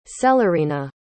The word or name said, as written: celerina